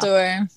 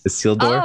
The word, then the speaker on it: Isildur: Isildur. – Isildur.